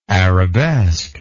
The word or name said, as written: arabesquely